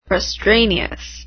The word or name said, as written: frustraneous